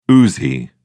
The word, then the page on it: uzi: British English